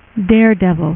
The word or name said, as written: Daredevil